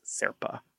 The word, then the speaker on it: Zerpa: We've been calling him Zerp dog, but I believe it is pronounced Zerpa.